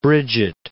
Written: Brigit

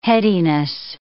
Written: headiness

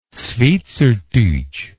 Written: schwyzertutsch

